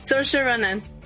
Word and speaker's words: Saoirse Ronan: Está interpretado por Soaris Ronan. Saoirse Ronan.